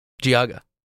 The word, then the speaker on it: Geauga: How do you pronounce the county? What's the county that's right below Lake? – Geauga. – There we go.